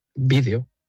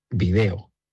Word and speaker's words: vídeo: Y vídeo es una palabra que utilizo mucho en mis clases, siempre con mis clases a cuestas, para explicar la diferencia entre palabras esdrújulas, vídeo, como lo pronunciamos en España, y palabras llanas, video, como lo pronuncian en Latinoamérica.